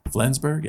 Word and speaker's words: Flensburg: Flensburg? Yeah, I said that right.